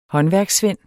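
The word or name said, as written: håndværkssvend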